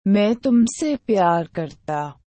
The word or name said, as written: ma i tumse pyar karta